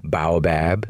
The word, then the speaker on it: baobab: So there's many ways to pronounce it. It can also be baobab.